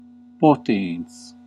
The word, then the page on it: powerful: American English; [ˈpa.ʊə.fʊl]